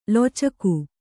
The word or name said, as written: ಲೋಚಕ